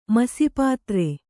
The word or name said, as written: ಮಸಿಪಾತ್ರೆ